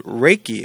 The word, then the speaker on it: Reiki: On today's podcast, we're highlighting Alice Langholt, Dr. Alice Langholt, who specializes in a unique area of health and wellness called Reiki. Am I pronouncing that correct? – That's perfect.